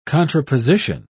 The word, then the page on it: contraposition: British English